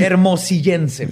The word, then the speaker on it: hermosillense: Hermosillense, perdón. – No, es que me quedé trabado ahí. hermollicense. – Es hermosillense.